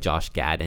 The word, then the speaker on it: Josh Gad: Like Josh Gad in it and yeah, I pronounced that right.